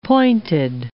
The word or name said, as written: pointed